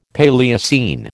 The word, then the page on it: Paleocene: American English